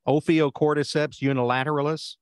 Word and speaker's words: Ophiocordyceps unilateralis: Ophiocordyceps unilateralis? – Yep, that's right.